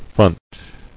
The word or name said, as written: Fronted